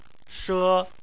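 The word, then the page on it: she: tone 1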